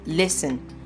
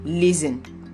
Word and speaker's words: listen: Whenever letter T appears in this combination, S-T-E-N or S-T-L-E, the T would be silent. So in the word listen, the T is silent, and you just say it as sun. S-T-E-N will sound as sun. So you say listen, not listing or listen, because the S is still having the S sound. So whenever you find this word or other words that have S-T-E-N in them, or that end with S-T-L-E, you will definitely know that the T should not be pronounced.